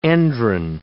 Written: endrin

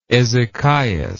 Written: ezechias

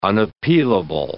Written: unappealable

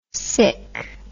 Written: sik

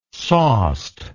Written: sauced

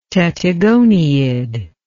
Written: tettigoniid

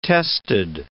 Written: tested

